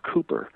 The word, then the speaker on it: Kuiper: Kuiper, not the same Kuiper as Abraham Kuiper, right. – It's spelled differently as well. And I guess the Dutch actually pronounce his name Cooper.